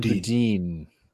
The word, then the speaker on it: Boudin: But then you do see this sort of strange thing where they elect um, Bowden, Boudin, uh, Bedouin. How do you say his name? Chesser Boudin. Ah. Yes.